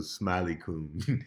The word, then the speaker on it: SmileeKun: It's created by Shit Posting, because I'm in shambles, and Smiley Coon, which is spelled SmileeKun. So Smiley Coon.